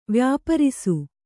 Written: ವ್ಯಾಪಾರಿಸು

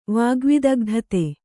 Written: ವಾಗ್ವಿದಗ್ಧತೆ

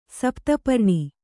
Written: ಸಪ್ತಪರ್ಣ

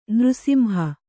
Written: ನೃಸಿಂಹ

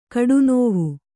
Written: ಕಡುನೋವು

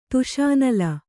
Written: ತುಷಾನಲ

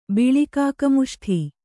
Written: ಬಿಳಿಕಾಕಮುಷ್ಠಿ

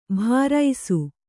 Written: ಭಾರೈಸು

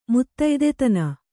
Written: ಮುತ್ತಯ್ದೆತನ

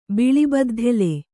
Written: ಬಿಳಿಬದ್ಧೆಲೆ

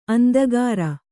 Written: ಅಂದಗಾಱ

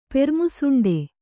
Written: ಪೆರ್ಮುಸುಂಡೆ

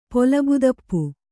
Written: ಪೊಲಬುದಪ್ಪು

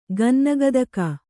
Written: ಗನ್ನಗತಕ

